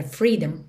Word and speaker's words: freedom: Atenção que esse O da palavra freedom praticamente não é pronunciado. Você não pensa em freedom. Você pensa em ir do D direto para o M.